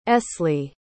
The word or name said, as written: esley